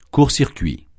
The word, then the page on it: court-circuit: French